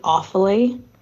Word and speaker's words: Offaly: I need to correct you on your pronunciations. It's Sligo, Mayo, and Galway. Then Armagh, Tyrone, and Offaly. And then Leitrim, Donegal, and Laois.